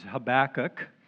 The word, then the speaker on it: Habakkuk: People pronounce it differently. The way I pronounce it is Habakkuk.